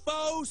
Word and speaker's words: faux: These are genuine faux sapphire earrings. 14 karat gold, 86 karat faux sapphire. Faux is a French word. It's got an X in it, but you don't even pronounce the X.